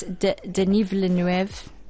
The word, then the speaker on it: Denis Villeneuve: It's Denis Villeneuve.